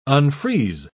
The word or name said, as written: unfreeze